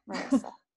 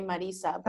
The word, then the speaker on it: Marissa: Marissa. – Perfect. – Or if you have a Spanish accent, you could say Marisa.